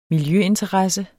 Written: miljøinteresse

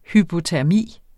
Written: hypotermi

